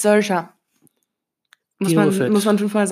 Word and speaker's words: Saoirse: Das ist Saoirse Ronan.